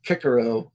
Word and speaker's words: Cicero: What does Cicero have to say about, or Cicero, as my friend pronounces it? – Yeah, it's funny.